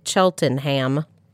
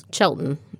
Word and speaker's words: Cheltenham: So it is spelled Cheltenham. If one were to say all of the letters, it looks like it should be Cheltenham. – But it's Cheltenham. No, take the middle out.